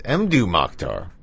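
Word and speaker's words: Mdou Moctar: I always say his name incorrectly. It's Mdu Mokhtar with a track called Tarhatest.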